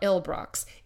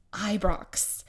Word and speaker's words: Ibrox: It is not Ilbrox, it's Ibrox.